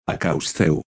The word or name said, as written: Açäwsthew